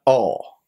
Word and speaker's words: awe: Se escribe muy fácil, A-W-E, pero se pronuncia O. Es como si fueras a pronunciar una A, pero que en vez de decir A dices O, pero realmente vas a querer decir es E. O sea, es como O, O, O.